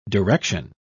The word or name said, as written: direction